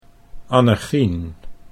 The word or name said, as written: Annechien